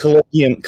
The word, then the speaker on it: Collegium: Collegium, Collegium Musicum. – Collegium, okay, Collegium, okay. European pronunciation.